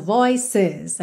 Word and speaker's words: voices: É interessante reparar que esse é pronunciado, voices. A gente realmente tem duas sílabas aqui, ok?